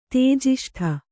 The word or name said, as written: ತೇಜಿಷ್ಠ